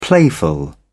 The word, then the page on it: playful: British English